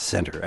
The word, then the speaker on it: Centre: Centre, Kentucky. I think it's pronounced center, actually, even though they spell it like the French.